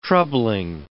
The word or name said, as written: troubling